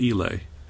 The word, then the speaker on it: Islay: And we have an Islay. – Lagavulin. – Yeah, the Lagavulin was yet another gift from Grandma Cat. – Now, it's actually pronounced Islay.